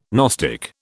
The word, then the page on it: gnostic: British English